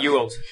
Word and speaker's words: Bejeweled: Sí, Bejeweled.